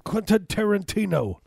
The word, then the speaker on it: Tarantino: Hey, Quentin Tarantino. That's how he would say. He would pronounce the T's.